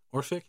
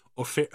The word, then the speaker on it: Orphic: Um, Or Orphic. How do you say that? Or Orphic? Okay. Orphic, Orphic hymn, uh, not myth.